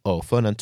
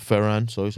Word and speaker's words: Ferran: Forwards, Fernand Torres. – Ferran.